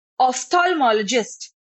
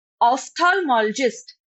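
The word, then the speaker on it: ophthalmologist: Okay और last word है बच्चों आज की हमारी क्लास का ऑफ्थोल्मोलॉजिस्ट। मैं फिर से रिपीट करती हूँ प्रोनाउंसिएशन, ऑफ्थोल्मोलॉजिस्ट। स्पेलिंग बताती हूँ आपको, OPTHALMOLOGIST, ऑफ्थोल्मोलॉजिस्ट, OPTHALMOLOGIST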